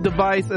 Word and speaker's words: DEV_IS: So there is no longer Hololive Japan, no longer Hololive English, no longer Hololive Indonesia, no longer Hololive device, which is not spelled actually device, D-E-V-I-S. Devis, device, it's pronounced device.